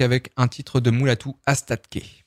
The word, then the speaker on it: Astatke: Astatke. Astatke. Faut pas oublier le T avant le K.